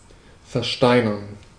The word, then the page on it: versteinern: /fɛʁˈʃtaɪ̯nəʁn/; [fɛɐ̯ˈʃtaɪ̯nɐn]